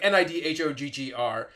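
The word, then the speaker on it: Níðhöggr: the eagles on top of Yggdrasil, like Vedfrenir, and I think the serpent, which I think is pronounced Nidhoggr. It's like Nidhoggr.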